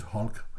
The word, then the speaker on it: Hulk: Og ordet Hulk, eller Hulk, som det hedder på dansk, men det udtales Hulk, det er et gammelt engelsk ord for altså en stor uformelig klump.